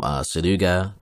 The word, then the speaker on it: Suruga: Yoshimoto Imagawa, a powerful warlord of Suruga, there we go, I said it right, I did it. he leads an immense army from Suruga towards Kyoto, in those days the capital of Japan.